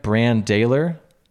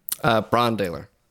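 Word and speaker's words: Brann Dailor: Bran Daler? – Bron Daler.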